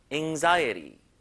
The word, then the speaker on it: anxiety: Anxiety. Anxiety. 자, 이게 A 발음이지만 약간 으 느낌이 나고요. Anxiety. Anxiety. 약간 이런 느낌으로 읽어주면 되거든요. 자, 중간에 강세 잘 주세요. Anxiety. 끝에 T는 약간 리을 발음처럼 설탄음으로 발음됩니다.